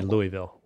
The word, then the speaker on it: Louisville: When I lived there, I called it Louisville, but now I say Louisville, which is the proper pronunciation.